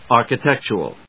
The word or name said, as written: architectural